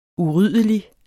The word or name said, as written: uryddelig